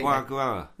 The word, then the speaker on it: foie gras: It's no, foie. It's, I... it's pronounced, and I've written it there for you. F W A G R A. Foie gras.